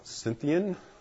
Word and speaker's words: Scythian: Where there is neither Greek nor Jew, circumcised nor uncircumcised, barbarian, I think you pronounce this Scythian?